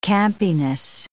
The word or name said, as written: campiness